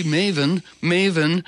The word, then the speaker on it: maven: I'll be Maven, you know, and may this station be Maven, which means information resource. It rhymes with Raven.